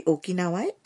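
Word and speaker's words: ওকিনাওয়া (沖縄): ওকিনাওয়ায় আপনি গিয়েছেন মানে সেখানে ভ্রমণের অভিজ্ঞতা আপনার আছে জাপানের সর্বদক্ষিণের দ্বীপ জেলা ওকিনাওয়ার জাপানি উচ্চারণ ওকিনাওয়া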